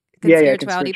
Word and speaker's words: Conspirituality: Because, and you mentioned some things I listened to you on the Conspirituality, am I saying that right? – Conspirituality podcast, yeah.